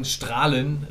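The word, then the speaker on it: Straelen: Wir werden irgendwie im Elfmeterschießen in Strahlen gewinnen, wie ich mir habe sagen lassen, wird das ausgesprochen.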